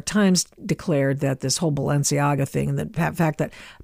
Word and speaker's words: Balenciaga: I want to say it right, Balenciaga.